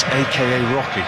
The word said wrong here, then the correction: A$AP Rocky: or A$AP Rocky, sorry.